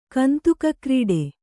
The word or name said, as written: ಕಂತುಕಕ್ರೀಡೆ